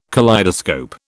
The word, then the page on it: kaleidoscope: British English